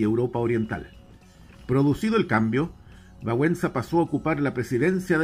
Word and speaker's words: Wałęsa: Otro caso emblemático es el de Lech Wałęsa, o Wałęsa como se pronuncia en polaco,